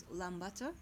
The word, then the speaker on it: Ulaanbaatar: And shortly, Ubi, but it is Ulaanbaatar. – Ulaanbaatar.